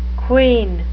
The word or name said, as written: quean